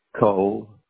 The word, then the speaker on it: Cole: Cole, C-O-L-E.